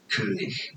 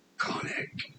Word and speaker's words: König: Name pronunciation in German. It is pronounced as König. – König. – König. König. In English. It's normally pronounced as Koenig.